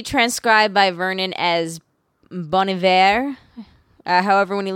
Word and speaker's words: Iver: I was like, Hiver. – Very that's the whole thing, is the H isn't pronounced, so that's why he can remove it. It doesn't change the pronunciation.